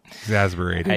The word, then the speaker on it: exasperated: Exasperated. – I don't. But look at, this is so weird that it is spelled E-X, but it's phonetically pronounced I-G-Z.